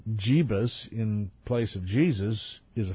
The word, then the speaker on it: Jeebus: Jeebus. And I didn't mispronounce that. That's what it says, J-E-E-B-U-S. Engel and Brown plan for the Charlotte event to launch a new national movement to oppress gay people, unquote.